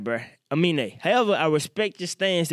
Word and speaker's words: Aminé: Like, by the way, I thought this fella's name was Anime. It's Aminé.